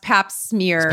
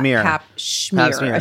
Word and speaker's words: pap smear: I actually believe it is pronounced pap smear, not pap schmear.